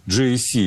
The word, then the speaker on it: GAC: Там штаб-квартира GAC, GAC, если правильно говорить.